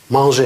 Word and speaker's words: mangé: Не, это не апостроф, это знак ударения, чтобы показать, что это «э», которое обычно в конце не читается, что оно читается. Манже.